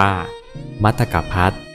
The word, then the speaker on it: มตกภัต: มัตกพัท